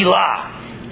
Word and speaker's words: Elah: If it were dual, meaning two gods, it would be Elah, E-L-A-H. But instead it's Elohim, E-L-O-H-I-M.